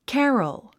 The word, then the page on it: carol: kǽrəl; キャロォ